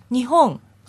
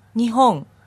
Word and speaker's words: 日本: 日本。 – Yes. One more time. – 日本。 – And break it down to syllables. – 日本。